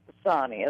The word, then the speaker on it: Sonni: No, Michelle, my name is pronounced Sonny, S-O-N-N-I.